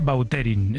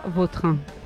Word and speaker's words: Vautrin: Es que cada uno a mí me suena mejor Bauterín. Es como... – Da más fuerza, ¿no? – Más fuerte, sí, más pronunciación. – Sí. Elegante. – La original francesa, Vautrin.